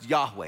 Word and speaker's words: Yahweh: The word in Hebrew, we think, is best pronounced Yahweh.